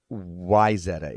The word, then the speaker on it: Wayzata: It's Wayzata. It's spelled Wayzata, yeah. But it's Wayzata.